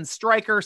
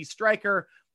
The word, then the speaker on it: Striker: Follow me, your host, at Twitter and Instagram at SN Striker, spelled with a Y.